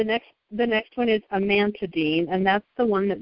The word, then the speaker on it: amantadine: Amantadine amantadine.